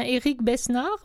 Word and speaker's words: Éric Besnard: Alors maintenant, pour savoir si jamais je n'ai pas fait d'erreur et il me semble que son nom se prononce comme ça, c'est bien Éric Besnard ou... – Je pense bien.